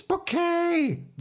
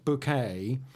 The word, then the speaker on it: Bucket: But she pronounces it immediately. She corrects him. It's bouquet. The first bouquet and she's appalled that he's delivering post to Elizabeth next door, but not to her.